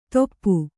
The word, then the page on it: ತೊಪ್ಪು: toppu